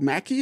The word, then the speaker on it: Mackey: I think it's called Mackey.